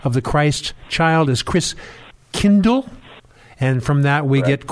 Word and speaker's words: Christkindl: And the German pronunciation of Christ child is Christkindl.